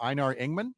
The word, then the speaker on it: Einar Engman: Einar Engman.